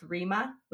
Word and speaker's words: Rema: I think it's pronounced Rima with Selena Gomez. – Okay. – I don't know if you're R-E-M-A.